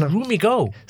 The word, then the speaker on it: Roomigo: It's Roomigo. – So it's like Room and Amigo kind of merged together.